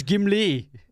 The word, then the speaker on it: Gimli: it's Gimli.